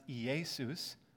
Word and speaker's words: Iesous: In Greek, they took Yeshu, because remember the New Testament is written in Greek, and they substituted an S sound for the shu sound at the beginning, and then they also added a little S to the end to spell it now I-E-S-O-U-S, which is pronounced Iesus.